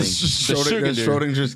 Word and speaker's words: Schrödinger: And it has an umlaut over it the O. Does, so it's, yeah. it's Schrödinger.